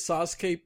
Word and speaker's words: Sasuke: And again, I said this last episode, but for additional emphasis, he pronounces Sasuke perfectly.